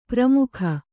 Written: ಪರಮುಖ

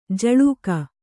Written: ಝುಳುಕು

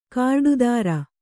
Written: ಕಾರ್ಡುದಾರ

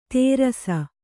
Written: ತೇರಸ

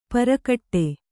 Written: ಪರಕಟ್ಟೆ

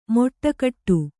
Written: ಮೊಟ್ಟಕಟ್ಟು

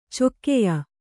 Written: ಚೊಕ್ಕೆಯ

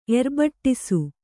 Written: ಎರ್ಬಟ್ಟಿಸು

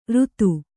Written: ರುತು

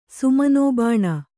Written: ಸುಮನೋಬಾಣ

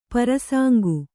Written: ಪ್ರಸಂಗಿ

